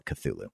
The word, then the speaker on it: Cthulhu: The second one was in an RPG that I had never played before, called the Call of Cthulhu, or you can pronounce it several ways. I went and looked it up, though, and this is the most common way it's said for the RPG. So I'm going to call it Cthulhu, which is the Lovecraftian world of cosmic horror.